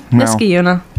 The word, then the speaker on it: Niskayuna: No, Niskayuna. He just pronounced the A a little more.